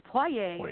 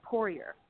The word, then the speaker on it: Poirier: So you're not even saying it correctly in the French. It's Poirier in the French, and it's Poirier in the American.